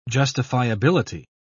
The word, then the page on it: justifiability: British English